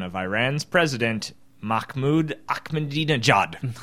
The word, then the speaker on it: Mahmoud Ahmadinejad: I'm fairly certain it's Mahmoud Ahmadinejad. – No, I think you're right.